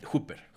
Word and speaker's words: Hooper: Escogí la película de Life Force de corrígeme la pronunciación, Toby Hooper o Toby Hopper. Hooper. Hooper, perfecto.